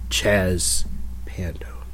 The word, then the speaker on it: Chaz Pando: In the sit-down with Chaz Pando.